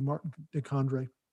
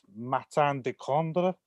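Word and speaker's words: Martin de Candre: So that's the only thing I would get in the way of shave soap would be Martin DeCondre. Is that, did I pronounce that right? Am I recalling that correctly? – My French is not brilliant, but I think it's Martin DeCondre.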